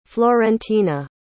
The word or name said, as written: florentina